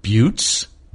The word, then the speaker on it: Butes: It's spelled B-U-T-E-S, which could be Butes, Boots, Beauties, Buties, Butus, Botus.